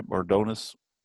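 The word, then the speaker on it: Mardones: Benny Mardonis, did I say that right, Mardonis? – Yeah.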